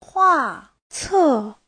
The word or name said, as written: hua4ce4